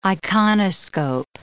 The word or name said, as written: iconoscope